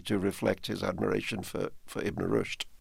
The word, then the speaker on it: Ibn Rushd: The story actually begins, eight centuries ago with a 12th-century philosopher named, I hope I pronounced this correctly, Ibn Rushd. – Yes.